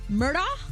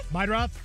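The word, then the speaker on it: Meidroth: Chase Myrdah? The White Sox guy? – Meidroff.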